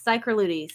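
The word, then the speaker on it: Psychrolutes: The blobfish is part of a genus of fish, which is called Cichrolutes. Did I say that right? – Yes, Cichrolutes.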